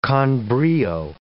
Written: con brio

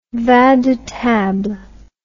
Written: vin de table

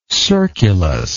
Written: circulus

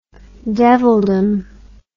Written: devildom

